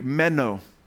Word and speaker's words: meno: Two syllables in the Greek, meno. The Greek word meno.